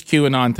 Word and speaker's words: QAnon: This QAnon thing, am I saying it right? – You are.